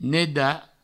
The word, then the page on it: nedar: neda